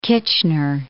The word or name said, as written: Kitchener